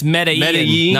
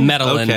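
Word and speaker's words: Medellín: It's Medellin, not Medellin.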